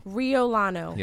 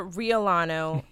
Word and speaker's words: Riolano: All right, Joseph and Victoria Rolle. Am I pronouncing the last name right? – Riolano. – Riolano. – Yes. – Riolano. And I said it confidently wrong. Joseph and Victoria Riolano.